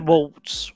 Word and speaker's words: Waltz: Christopher Waltz or Voltz. I don't know how you're supposed to pronounce that Voltz since it's a German name or Waltz. – I think it's Waltz.